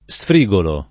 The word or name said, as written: sfrigolare